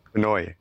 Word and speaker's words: Pinoy: See, so real quick, you guys are saying a little bit wrong. – Aha! Uh-huh. Oh, how you say it? – It's Pinoy. – Pinoy. – Pinoy. – Pinoy. – Pinoy. Yeah, like Pinoy.